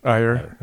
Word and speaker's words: Iyer: How do you say his last name? – Iyer.